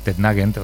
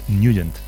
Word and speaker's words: Nugent: Hombre, siempre lo hemos llamado en el barrio Tednagent o Tednagent. – Claro, pero yo era de Tednagent. – Pero la pronunciación correcta es Nugent.